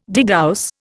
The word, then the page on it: degauss: Indian